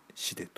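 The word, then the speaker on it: 死出: 平成 3 年 5 月 3 日午前 0 時市で、死亡の死に出口の出で死でと読むんですが、死での旅を意味する真っ白の死で装束に身を包み凛とした空気の蔵王堂で本尊蔵王権現に大峰千日開放に入業させていただきます。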